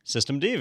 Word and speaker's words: systemd: Systemd, very good.